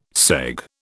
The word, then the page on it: segue: British English